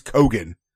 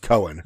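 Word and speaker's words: Keoghan: Okay, it's the Irish Gaelic way of like Keegan. It's like phonetically, it's Cogan. – Okay. – But it's pronounced Cohen.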